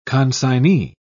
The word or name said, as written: consignee